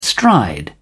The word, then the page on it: stride: British English